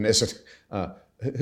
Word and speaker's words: Knesset: I can't pronounce the Knesset. – It. Knesset.